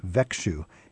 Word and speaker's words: Växjö: It's V-A-X-J-O. – And it's pronounced Växjö.